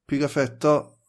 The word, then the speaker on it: Pigafetta: Ja, det är ju Pigafetta, så uttalar jag hela.